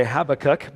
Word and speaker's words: Habakkuk: So we've been looking at this prophet named Habakkuk, and at least you've learned that there is a prophet named Habakkuk, or you know how to say it now, right? You've always wondered how to pronounce that, and or some people say Habakkuk,